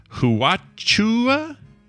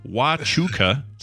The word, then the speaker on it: Huachuca: He says, Huachua, anyway, is pronounced Huachuca.